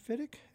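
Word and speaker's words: Fidyk: That was Steve Fittick's quintet from his new album Heads Up, and that was some beautiful flugelhorn work by Terrell Stafford.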